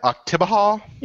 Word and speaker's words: Oktibbeha: How do you say that? Oktibbeha? – Yeah, it's Choctaw.